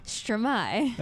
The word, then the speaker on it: Stromae: People call him Stromae, it's correct. – No, if you watch his interviews, he pronounces it Stromae.